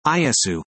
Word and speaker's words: Iesu: The name of Jesus in Greek is Iesu, pronounced Iesu.